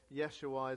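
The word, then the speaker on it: Yeshua (ישוע): The Hebrew pronounced this Yeshua is a combination of Yah for Yahweh. We see that in the book of Exodus. And Yasha meaning rescue or to save.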